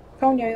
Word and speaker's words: Ponyo: Ponyo, that's right.